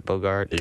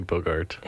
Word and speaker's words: Bogart: Bogart? – You kept asking that in the movie. Was it Bogart or Bogar? – Yeah, because I'm like, it's definitely Bogart.